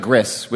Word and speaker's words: gris: That's G-R-I-S? The French. Like the French, the gris. – Gris. – You know. – Sure. That's not. Yeah. – So what is it? – That sounds better than gris, which sounds like something I would eat.